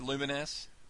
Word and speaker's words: Lumines: Yes, Lumines is a pretty awesome game. – Yeah. Is that how it's said, Lumines? – Yes.